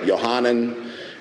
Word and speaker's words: Yohanan: It would have been pronounced back then like Yohanan, and from the Hebrew Yohanan, which means God has been gracious.